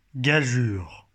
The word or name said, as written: gageure